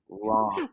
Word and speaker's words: Ran: Okay, Ron.